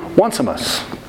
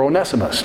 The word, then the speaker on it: Onesimus: It's a personal letter from Paul to his friend, Philemon, regarding the status of Philemon's slave who was named Onesimus. Or Onesimus, depending on how you say it.